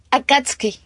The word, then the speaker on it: Akatsuki: Akatsuki.